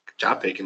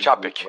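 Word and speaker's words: Čapek: Eu não sei se tu, colo se tu colocaria, o Aí o, o, quem souber a pronúncia vai me perdoar, do cara é o Capek. Chapek, não é? É, Chapek, é. – é. eu aprendi Chapek, depois de um é.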